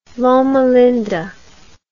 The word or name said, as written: loma linda